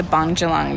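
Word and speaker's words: Bundjalung: One of our other RDs, Jason, has just told us we've been saying it wrong, but how I believe you say it is the Bungelung Nation.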